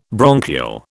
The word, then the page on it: bronchial: British English